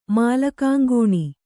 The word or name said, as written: ಮಾಲಕಂಗುಣಿ